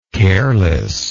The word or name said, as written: carelessness